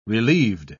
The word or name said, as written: relieved